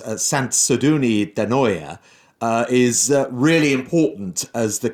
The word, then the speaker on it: Sant Sadurní d'Anoia: Well, you pronounce Sant Sadurní d'Anoia much better than I pronounce the English language. But yes, Sant Sadurní d'Anoia is the capital of Cava, is the town that gathers most of the cellars.